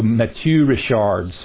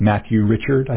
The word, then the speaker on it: Mathieu Ricard: It's Mathieu Ricard, so like Matthew Richard, I think, is the way you would pronounce it in English if you weren't trying to be fancy. And Matthew Richard. So, but I think that's about as close as I can get to the true pronunciation.